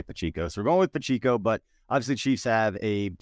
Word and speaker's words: Pacheco: Really, Isaiah Pacheco. That's what I've been told in the comment section, and that's what I've been messaged at that I've been consistently saying it wrong. So Pachico. I have like cheeks.